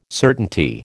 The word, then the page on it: certainty: American English